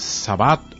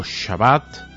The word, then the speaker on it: Shabbat: El Sabbat o Shabbat, depende la pronunciación con que tomemos estas palabras en idioma hebreo, es el día sagrado, es el último día de la semana.